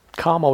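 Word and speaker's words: kāma: There is kama with a short A, has meaning action, and there is kama meaning sensuality.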